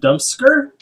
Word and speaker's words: Dumpsker: Which is also, we should probably preface, it's pronounced Dumpsker. – Dumpsker. – Like the Oscars. – Yes. – So it just but looks like Dump Scar.